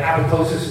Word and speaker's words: apoptosis: It's called apoptosis, spelled apoptosis, but apoptosis is how you pronounce it.